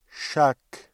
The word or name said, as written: chaque